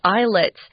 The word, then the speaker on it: islets: It's made up of about 90 islets. Notice here we're not pronouncing the S. It's not islets. It's islets, which is kind of weird.